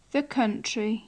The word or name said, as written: the country